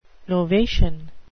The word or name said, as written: novation